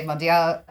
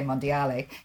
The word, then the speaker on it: Mondial: Well, it depends if you talk to my French-speaking partners globally or my Italian-speaking partners globally. My French-speaking partners would say Mondial Impact. My Italian-speaking partners tell us that we need to get the pronunciation right and say Mondiale.